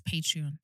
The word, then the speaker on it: Patreon: Is that how you say it? – Yes, Patreon.